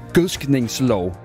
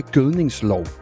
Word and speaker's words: gødskningslov: med at udtale det, som det hedder, nemlig gødskningslov, og ikke gødningslov, som rigtig mange siger.